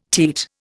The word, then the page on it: teat: Indian